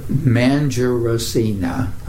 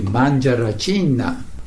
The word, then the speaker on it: Mangiaracina: Mangiaracina. – Okay. – But the Italian would be Mangiaracina.